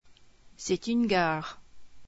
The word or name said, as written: gare